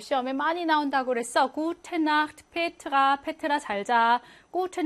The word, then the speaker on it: Nacht: 요 Nacht의 체하를 어떻게 발음하냐인데요. 자, 아나, 오나, 우나, 아우 다음에 체하가 오면 이 아, 오, 우, 아우라는 모음들은 얘네 모음은 이혀 중에서도 혀 뿌리 쪽에서 뒤쪽에서 후설로 나기 때문에 아, 오, 우, 이게 아, 오, 우 뒤쪽에서 나기 때문에 목구멍 마찰음인 체하, 흐, 아, 오, 우, 이렇게 가까운 쪽으로 나는 게 좋다는 거지. 그래서 나흐트고로 발음하는 게 좋다는 거예요.